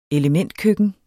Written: elementkøkken

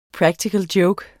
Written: joke

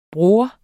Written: bror

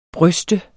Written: bryste

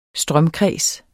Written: strømkreds

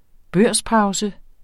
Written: børspause

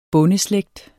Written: bondeslægt

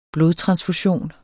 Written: blodtransfusion

